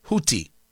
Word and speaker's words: Houthi: You have the Iranian-backed Houthi rebels, H-O-U-T-H-I, just so you know how I'm pronouncing it. It's pronounced Houthi. It's not Thi. It's not Houthi. Houthi. Well, so the H is silent, but it's spelled H-O-U-T-H-I.